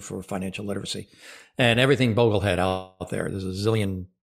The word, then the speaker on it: Boglehead: And that was a specific question, was talking about the moment you became, and I've practiced pronouncing this correctly, it's Boglehead, correct? – Correct, yeah. Boglehead.